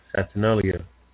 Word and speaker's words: Saturnalia: To be honest, you know, it's the Saturnalia. I'm not sure if I'm pronouncing that right. – Right, Saturnalia.